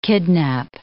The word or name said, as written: kidnap